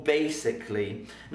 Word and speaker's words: basically: Now basically has a silent letter because I don't say basically, I say basically, not basically, basically. So the A is silent because we don't say it. So vaccinate, we say both A's, vaccinate, Amsterdam, we say both. Basically, I skip the A, so well done.